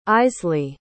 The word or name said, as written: isley